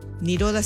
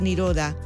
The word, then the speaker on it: Nirodha: Niroda se escribe N-I-R-O-D-H-A. Espacio Niroda.